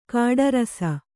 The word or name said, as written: ಕಟ್ಟುರಸ